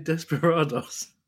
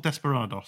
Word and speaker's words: Desperados: Why are you pronouncing it Desperados? – What is it called? – Surely it's not. I thought it was just Desperados, isn't it? It's got an S on the end.